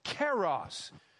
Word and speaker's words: kairos: He uses a word that is pronounced something like this, kairos. You could spell it if you want it, if you care about it, K-A-I-R-O-S, K-A-I-R-O-S.